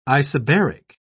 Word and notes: isobaric: British English

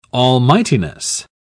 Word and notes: almightiness: British English

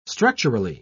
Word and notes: structurally: British English

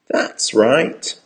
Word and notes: that's right: American English

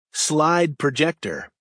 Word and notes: slide projector: American English

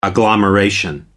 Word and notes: agglomeration: American English

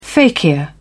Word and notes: fakir: British English

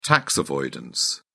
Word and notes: tax avoidance: British English

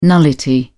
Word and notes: nullity: British English

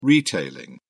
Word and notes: retailing: British English

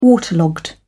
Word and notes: waterlogged: British English

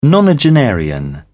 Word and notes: nonagenarian: British English

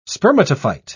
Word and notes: spermatophyte: British English

